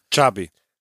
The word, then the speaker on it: Xavi: He said, say it like it's T-C-H-A-B-E-E. – Xavi.